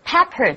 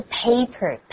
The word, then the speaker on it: pepper: 对啊，因为这个 pepper 中音发音哦，不是 paper, paper 是纸 ,pepper 是胡椒。Black pepper 是黑胡椒，而 white pepper 呢，则是白胡椒的意思。不过啊，我们刚刚讲说，就是你喜欢的那个对象啊，在英文当中直接用 crush 这个字就好了。